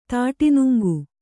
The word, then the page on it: ತಾಟಿನುಂಗು: tāṭinuŋgu